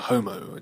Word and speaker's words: homo: If you use the prefix on its own, if you call someone a homo, it sounds like that, it rhymes with promo.